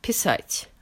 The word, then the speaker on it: писать: Let's take the verb писать, stress on the last syllable, which means to write, to draw, or to compose.